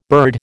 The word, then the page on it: bird: American English